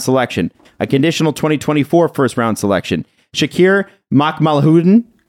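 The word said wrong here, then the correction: Mukhamadullin: Mukamadulin. – Mukamadulin, excuse me.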